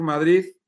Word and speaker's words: Madrid: Madrid terminado en Z, ¿verdad? Los madrileños pronunciamos Madrid. Eh, Madrid termina en D, la letra D de dedo, pero la, la, pronunciamos así como un poco fuerte, ¿no? Casi como Z, ¿no?